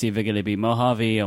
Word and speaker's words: Mojave: It's French. It's Mojave. – I think it's Bondi. Bondi, I think. – So I had said, Mojave with an E. That spread rather quickly, I feel like, to some other podcast that I think one even credited me for, like, oh, Steven told us how to say it.